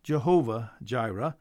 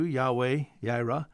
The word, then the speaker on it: Yahweh Jireh: You may hear this as Jehovah Jireh, but it's more probably pronounced in Hebrew, Yahweh Jireh, means the Lord will provide.